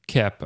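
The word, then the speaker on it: Koepp: What was it, David Cope? – David Kep, I think it's pronounced. Kep.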